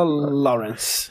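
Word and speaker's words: Laurence: Escreve Lorink, mas fala Lawrence.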